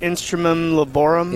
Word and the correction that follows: Instrumentum Laboris: Instrumentum Laboris.